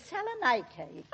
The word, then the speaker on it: Salonika: If you'll excuse me, Mrs. Twimley, the correct pronunciation is Salonika.